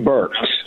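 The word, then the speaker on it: Birx: But according to some answers we got about masks here just recently, according to, is it Dr. Bricks? Is that her name? Is that how she pronounces her name? – Burks. – Mm-hmm. Burks, okay.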